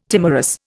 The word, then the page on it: timorous: Indian